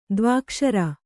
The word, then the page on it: ದ್ವ್ಯಕ್ಷರ: dvyakṣara